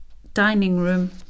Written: dining room

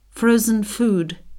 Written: frozen food

